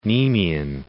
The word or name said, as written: Nemean